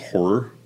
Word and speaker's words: HO99O9: Number five, I have, this is probably the dark horse that I don't think any of you guys will have, is a group called Horror, but it's spelled H-O-9-9-O-9.